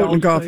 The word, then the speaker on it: Totenkopf: He called it my Totenkopf, or if I'm pronouncing it correctly. – Yep. Totenkopf, yeah.